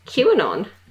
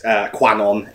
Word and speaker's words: QAnon: Which you don't pronounce like croissant, as no. Has been much discussed. – No. Yeah, we have Tal who pronounces it QAnon. It is not QAnon.